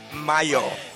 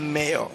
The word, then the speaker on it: Mayo: Yo, it's Mayo, not Mayo.